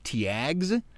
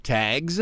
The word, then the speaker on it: Tiags: That's by a guy named, it's spelled T-I-A-G-S, Tags. Tags.